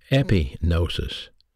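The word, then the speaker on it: epignosis (ἐπίγνωσις): G-N-O-S-I-S. Gnosis is the word knowledge. But they also had another word for knowledge, pronounced epignosis.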